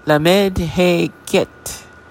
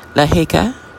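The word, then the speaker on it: Lamed He Chet: Lamed, L-A-M-E-D, He, H-E-Y, Ket, C-H-E-T. Sometimes I wonder if I'm pronouncing that correctly, even when I get verification that I am. This is why I spell it. And there's no intention of being condescending there. We're just trying to help each other out. Another pronunciation is L-A-H-E-C-H-E, Laheka. So the Chet or Ket is pronounced in the Spanish tradition. Laheka. Okay. That's another acceptable name for this guardian.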